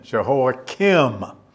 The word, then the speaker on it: Jehoiakim: It's spelled K-I-M at the end, Jehoiakim.